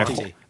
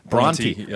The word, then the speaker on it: Bronte: Am I pronouncing that correctly? – Bronte. – Bronte. I even looked it up today. Like my whole life, I've been saying it in my head, I think, the wrong way. Bronte.